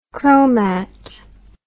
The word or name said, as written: chromat